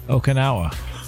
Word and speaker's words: Okinawa: How you say it? I mean, how are you supposed to say Okinawa? – Okinawa.